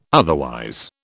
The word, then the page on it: otherwise: British English